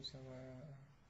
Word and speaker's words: سواء: ليسوا سواء، هي اذا كان الهمزة اخر شيء ونونت ما تضيف الف، ما تضيف الف، لكن في النطق تقرأ بالف، تقول ليسوا سواء، ما تقول سواء، لكن فانبذ اليهم على سواء لانها مجرورة مثلا.